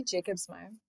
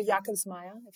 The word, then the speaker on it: Jakobsmeier: We could say Jakobsmeier. – Jakobsmeier. – Or Jakobsmeier.